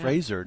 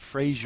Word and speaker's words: Fraser: Fraser, not Frasier. – Fraser. – Yeah, he's in an interview with Adam Sandler, and it's Fraser, apparently. There's no I, just Fraser.